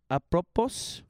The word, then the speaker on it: apropos: Apropos. – Uh-huh. – Did I say that right? – Yeah.